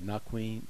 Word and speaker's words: Naquin: And also, I don't like to, you know, correct pronunciation, but the other one is Naquin, Naquin. – Okay, where is the okay? – No, that was earlier when you said Naquin, Naquin. – Oh, okay. – It's Naquin.